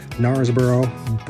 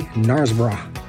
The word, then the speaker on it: Knaresborough: But my first day trip was to Knaresborough, and I'm going to say it like we would say it in America, Knaresborough, but the way they say it here, if you were to say it with a British accent, it would be Knaresborough.